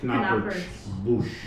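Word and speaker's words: Knappertsbusch: Is it, how do you pronounce the conductor's last name? Knoppersbusch? – Pronounce K, so it'd be Knoppersbusch.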